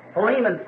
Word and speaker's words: Philemon: So Philemon someone said back there, which I think is really the correct pronunciation of it.